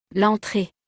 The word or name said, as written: l'entree